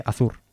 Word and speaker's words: Azur: Azur. Lo he dicho bien, ¿eh? No es que me haya equivocado.